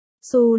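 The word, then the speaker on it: Hsu: Su là một trong nhiều họ trong tiếng Anh, trong từ này, chữ H là chữ cái đầu không phát âm, toàn bộ từ được phát âm là Su,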